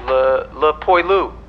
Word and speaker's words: le poilu: Le poilu. Did I say that right? I think they're called that because the French are a hairy lot. – Pretty good. And I was a French major, so I should know.